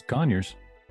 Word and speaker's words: Conyers: Did I say that right, Conyers, Georgia? – Yeah. Oh, it's Conyers. – Yeah, it's Conyers.